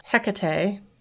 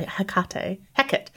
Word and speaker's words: Hekate: Well, Hekate, I need to pronounce it differently, Hekate, she did not lose her powers.